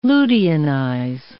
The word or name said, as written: luteinize